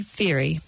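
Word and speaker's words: Fairey: Just search for Julie Ferry. That's spelled F for Fred, A-I-R-E-Y.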